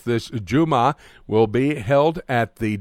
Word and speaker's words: Juma: And they call it, and how do you pronounce from the Arabic word, J-U-M-A-H? – That's the word Juma, which means Friday.